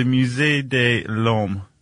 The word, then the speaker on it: Musée de l'Homme: The Musée de l'Homme.